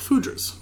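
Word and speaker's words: foudre: The aging vessel was Oak Fudra's, which we've now have decided that's the commonly accepted uh, pronunciation.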